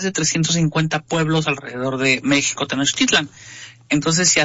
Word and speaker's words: México: Voy a empezar desde la pronunciación original de México en náhuatl, era México con uh-huh. acento en la I. – Uh-huh. – Y la pronunciación era como shampoo. Ajá. O shower en inglés.